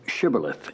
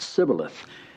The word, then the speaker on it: shibboleth: Then said now unto him, say now shibboleth. And he said sibboleth, for he could not frame to pronounce it right.